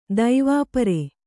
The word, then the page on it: ದೈವಾಪರೆ: daivāpare